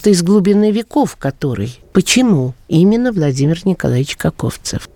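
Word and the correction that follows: Коковцов: Если позволите, я сразу поправлю фамилию со спорным ударением, потому что... – Каковцов? – Да. Его предки, его, ну, скажем так, деды, прадеды, возможно, были Каковцевы.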